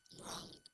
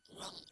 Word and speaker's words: wrong: ¿Cómo se dice equivocado? – Wrong. – Wrong, again. – Wrong. – Eso es. La W es completamente nuda.